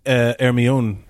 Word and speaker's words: Hermione: In my head, I'm like, Hermia. And then, like, in the movie, it wasn't until the movie when I heard them say her name, and I was like, Well, and it's a French name, but it's actually pronounced Hermione.